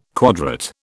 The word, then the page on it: quadrate: British English